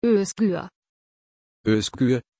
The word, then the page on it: özgür: German